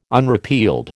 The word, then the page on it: unrepealed: American English